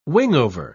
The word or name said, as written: wingover